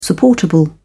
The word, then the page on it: supportable: British English